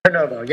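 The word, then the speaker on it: Prenuvo: Did I pronounce that right? A Prenovo scans? – Prenovo, yep.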